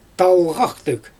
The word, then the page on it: talɣaxtɯɣ: Xyzyl